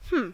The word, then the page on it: hmm: American English